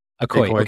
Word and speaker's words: ACOY: Is that group of people, those artists who are trying to figure all of that out, is that why you started ACOI? Am I saying that right? How do I pronounce the Yeah, that's name? Right. – ACOI.